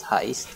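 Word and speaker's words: HE1ST: It's H-E-1-S-T, but pronounced as Heist.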